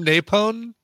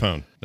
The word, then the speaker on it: Apone: Why are you calling him Napone? – Napone, that's how you say it. – Just Napone. – Oh, when I say Napone, I meant Apone. Oh, yeah, you're right. Apone.